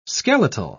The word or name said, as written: skeletal